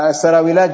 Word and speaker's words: السراويلات: السراويلات جمع سراويل, هي هكذا تنطق, هي هكذا تنطق.